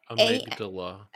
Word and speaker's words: amygdala: Is that how you say it? – Amygdala. – Amygdala. – I also would like for everyone in the audience to know that I'm guessing based off of her pronunciation. – No, you're right. It's amygdala.